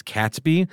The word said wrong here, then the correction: Catesby: Mm-hmm. It's apparently Catesby.